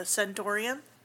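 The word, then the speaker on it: Centorium: I really liked the old name, the Centaurium, rhymes with Emporium.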